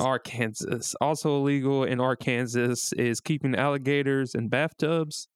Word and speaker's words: Arkansas: State code strictly reinforces that Arkansas is pronounced one way and one way only, three syllables with the accent on the first and last syllable and a silent S at the end.